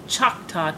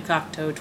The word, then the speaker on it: Cocteau: I messed up many transitions during my 6 years in the booth, and my most egregious mispronunciation was Choctaw Twins for the Cocteau Twins.